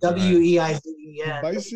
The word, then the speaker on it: Weizen: The W is pronounced as a V sound. – Right. – A Weizen, like it's spelled W E I Z E N.